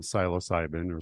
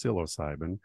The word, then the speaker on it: psilocybin: you conducted legal clinical trials of psilocybin, or sometimes it's pronounced psilocybin, the primary chemical found in the magic mushrooms.